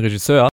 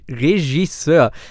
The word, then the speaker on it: Regisseur: Ich würde sagen, ich merke schon, ich plaudere hier mit dem nächsten großen Regisseur. Übrigens, Props an dieser Stelle, dass ich Regisseur richtig ausgesprochen habe. Kannst du das richtig aussprechen? Regisseur.